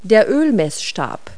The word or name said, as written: dipstick